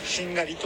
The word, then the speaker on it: 殿: 殿」と書いて「しんがり」と読む